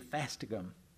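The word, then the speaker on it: fastigium: He is the fastigum. You know that word? – Nope. – Oh, good. I'll spell it for you. F-A-S-T-I-G-U-I-U-M. Pardon me. G-I-U-M. Fastigum.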